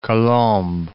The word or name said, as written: Colombes